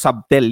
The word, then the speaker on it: subtle: Hindi po subtle yan. Subtle po ang pagka-pronounce niyan.